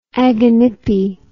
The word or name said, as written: aganippe